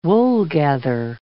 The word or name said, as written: woolgather